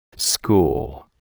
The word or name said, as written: school